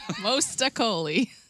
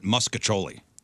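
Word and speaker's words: mostaccioli: They pronounce it Muscacholi. – Choli? Where's the H? – Yeah, there's a lot of things missing.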